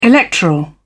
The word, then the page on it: electoral: British English